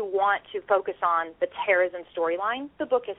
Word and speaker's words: Terezin: Did I say that right, Terezin? – Yes.